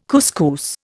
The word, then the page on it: couscous: Indian